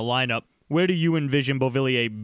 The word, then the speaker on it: Beauvillier: Sticking with the youth also, I know you're a big fan of Beauvillier. I actually said it right that time.